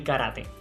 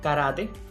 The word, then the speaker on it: karate: Entre estos deportes está el karate. A propósito, recordamos que tanto karate, pronunciado karate así, tal cual lo escucha, como karate, son grafías apropiadas